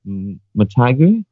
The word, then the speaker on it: Motagua: I'm bound to pronounce this wrong as well, but Motagua? – Yeah, that's pretty good.